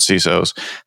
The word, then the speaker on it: CISO: I actually finally decided on the pronunciation of that when at one of our CISO summits, Brett Arsenault asked the audience of these top 50 or 100 CISOs, how do you actually pronounce it? And it was like overwhelmingly CISO. So that's actually what I go with.